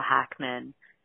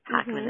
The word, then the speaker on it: Hackman: I'd advise you to just Google my name, which is Michelle Hackman. Hackman is spelled H-A-C-K-M-A-N.